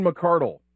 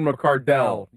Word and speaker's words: McCardell: Keenan McCardle became Keenan McCardell.